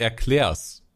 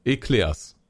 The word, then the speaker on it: Eclairs: köstlicher Erklärs mitbringen könnten, könnte. – Erklärs. – Eclairs.